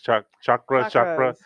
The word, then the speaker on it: chakra: So can I ask, it may be related, may not, but what are these in relation to the, like the chakra, am I saying that right? Chakra. – Yeah.